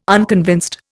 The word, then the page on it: unconvinced: Indian